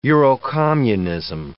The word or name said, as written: Eurocommunism